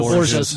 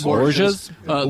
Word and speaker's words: Borgias: Is it the Borgias? How do you say that? – The Borgias. – Borgias.